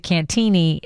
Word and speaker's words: Cantigny: Just so you know, 708, the previous texter corrected us to Cantini.